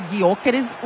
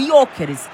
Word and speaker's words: Gyökeres: Por exemplo, na Suécia, esse G é mudo, tanto que fica, em vez de Gyökeres, o Jokeres.